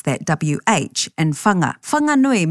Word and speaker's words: Whanganui: So the H in Whanganui is there, but it's heard as a breath, as they describe it. – In Whanganui, the H is aspirated. So it's not a wha, it's a wha.